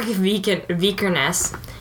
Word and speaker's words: Vikernes: So it's about Varg Vikernes, and that's how I'm going to pronounce that word. Vikernes.